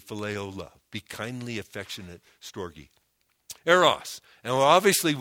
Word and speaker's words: storge: It's pronounced storge, is the love one has for a dependent.